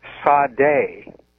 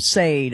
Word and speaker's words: Sade: And at one point, one of them telepathically told her to call either, it was either her or the group, Sade, S-A-D-E, she said is how it's spelled.